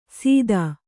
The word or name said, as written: ಸಿತ